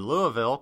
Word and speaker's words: Louisville: Louisville, Kentucky.